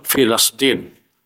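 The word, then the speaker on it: Filastin (فلسطين): Nah, ini bacanya itu bukan Filistin tapi Filastin. Ya. Nah, kadang salah ya. Min ain Filistin. Nah, salah. Ya betul, Filastin. Lamnya difathah.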